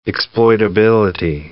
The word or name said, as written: exploitability